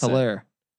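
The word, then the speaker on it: Helaire: They got a Clyde Edwards Hilary out of LS Hilary. How do you pronounce Hilaire. – It? Hilaire. – Yeah. It's French, bro.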